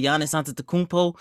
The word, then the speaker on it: Giannis Antetokounmpo: Chris Paul isn't playing well in the NBA playoffs, and my boy Giannis Antetokounmpo got hurt last night, man.